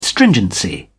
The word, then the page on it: stringency: British English